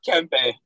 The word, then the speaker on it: Kempe: Was it Kempe? – Kempe, yeah.